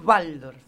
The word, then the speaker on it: Waldorf: Está bien, Waldorf. Igual, nosotros, claro, bueno, no, sí, es sí, que sí. Nosotros pronunciamos así, está bien, yo digo Waldorf porque sé que se dice como B, pero...